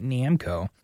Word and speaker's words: Namco: Of course, the fighting game from Bandai Namco. Why did I say it like that? Namco.